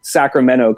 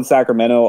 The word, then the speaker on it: Sacramento: Yeah, actually, you're the one person I've ever met from outside of this area that actually pronounces Sacramento correctly. Everyone says Sacramento, but we hear Sacramento. Yeah, the T is silent. People don't know this, but you can always tell. Nobody says the T.